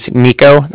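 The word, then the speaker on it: Niko: So like I said, my name's Nico. That's N-I-K-O. – Okay. – And then the Bank of Cardiff is spelled C-A-R-D-I-F-F, as in Frank.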